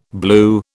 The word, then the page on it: blew: British English